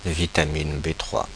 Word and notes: vitamine B3: /vi.ta.min be tʁwa/; France (Paris)

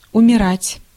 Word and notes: умирать: [ʊmʲɪˈratʲ]

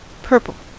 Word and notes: purple: /ˈpɜː(ɹ).pəl/

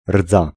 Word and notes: rouille: [ʁuj]; France

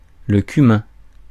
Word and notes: spice: /spaɪs/; American English

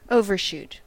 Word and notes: déborder: France; IPA: [de.bɔʁde]

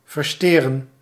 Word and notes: massacrer: France; [ma.sa.kʁe]

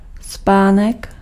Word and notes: tempe: France; [tɑ̃p]

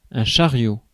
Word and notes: cart: /kɑːt/; /kɑɹt/

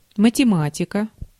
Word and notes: mathematics: American English

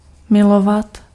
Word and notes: milovat: /ˈmɪlɔvat/

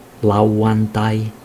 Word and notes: sabato: Italian

